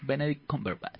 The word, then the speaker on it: Cumberbatch: Benedict Cumberbatch.